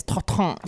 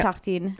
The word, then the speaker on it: tartine: And I know already that it's not tartine, it's tartan. So I feel like I'm doing that's spelled tartine. – No, it's tartine. It's tartine. It has an E at the end.